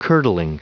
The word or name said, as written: curdling